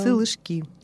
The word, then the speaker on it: сылышки: Ударение на последнем слоге, да. «Сылышки».